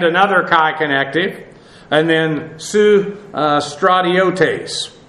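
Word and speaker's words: su stratiotes (συστρατιώτης): This adjective, or this noun actually, S-U-S-T-R-A-T-I-O-T-E-S. This has the idea of a soldier or one who fights, and then the soon prefix, it's actually soon, but phonetically it goes to an S there, has the idea of with, an accompaniment with.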